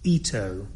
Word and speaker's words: Ito: Ito. It's written I-T-O, but pronounced Ito.